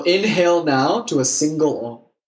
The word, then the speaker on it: om: If you'd like, you can join me for om. It's four syllables. Ah, oo, om. The fourth syllable is silence.